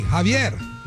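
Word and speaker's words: Xavier: Spelled with an X, not with a J. Not with a J. Javier. Like the guy who's running for governor of our great state of California. Like same guy. Well, not the same guy, but same name. Said the same way.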